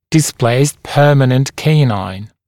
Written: displaced permanent canine

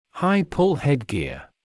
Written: high-pull headgear

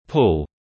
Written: pull

